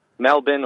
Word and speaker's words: Melbourne: They pronounce it Brisbane or Melbourne. It's just Melbourne or Brisbane. Just imagine the end of the bin.